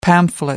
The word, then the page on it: pamphlet: American English